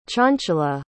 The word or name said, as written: chanchala